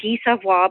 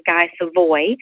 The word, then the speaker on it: Guy Savoy: and also a chef named Guy Savoie, but most people who don't speak French would recognize his name pronounced as Guy Savoy, and also Charlie Trotter there in Chicago.